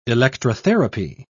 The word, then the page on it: electrotherapy: British English